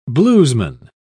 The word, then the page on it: bluesman: British English